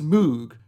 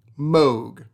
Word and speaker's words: Moog: After the first day of class, the children began to moo at her because her name was Mrs. Moog, to which Bob simply changed the way he pronounced his last name to the way many of his cousins pronounced his name, Moog.